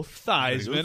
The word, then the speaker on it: Theismann: And he's like, you know, I'm going to go Joe Theismann. And it rhymes with Heisman, and he's still known as Joe Theismann.